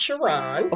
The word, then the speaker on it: Sherron: Um, it's pronounced Sharon.